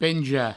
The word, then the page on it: penjar: Provençal; penja